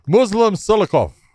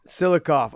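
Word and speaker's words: Muslim Salikhov: Muslim Silikov. – Silikov.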